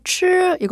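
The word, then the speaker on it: 吃: 이 발음을 먹다, 추 이거 혀 말아서 하는 발음이랑 헷갈리시는 분들이 많거든요. 그래서 가다는 취, 끝까지 입을 동그랗게 유지한 채로 사성으로 발음을 하고 먹다는 혀를 약간 말아서 추라고 발음해 주세요.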